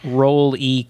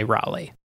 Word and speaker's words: Rolly: And I have written out Rolly Crump phonetically because I always say Raleigh.